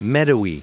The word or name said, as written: meadowy